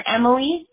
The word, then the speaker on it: Emily: Sure, Emily, E-M-I-L-Y. – Okay. – And my last name is spelled K-I-N-S-E-L-L-A.